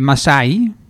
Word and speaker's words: Maasai: En het gaat over de Maasai, zeg ik het goed? – Ja, ja, zeker.